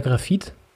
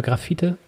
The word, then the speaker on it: Grafite: ist so ja, es gibt, du kannst beides irgendwie, also er selber ähm, sagt wohl eher Grafit oder Grafite, Graphit.